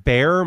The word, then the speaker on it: Baer: My name is Pat Baer. My last name is spelled B-A-E-R.